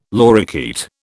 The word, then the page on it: lorikeet: British English